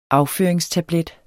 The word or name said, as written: afføring